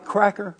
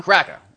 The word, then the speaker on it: Cracka: When you say Cracka, are you talking about white Cracka? – Yeah. Yeah. And you got to say it right. It's Cracka. Not Cracka. Cracker.